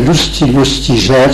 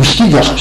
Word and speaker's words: Lustiger: Le nouvel archevêque de Paris, qui s'appelle Lustiger, si on prononce en français, ou Stiger, si on prononce en allemand.